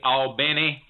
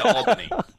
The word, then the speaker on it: Albany: Well, the natives say Albany. We say Albany.